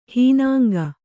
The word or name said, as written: ಹೀನಾಂಗ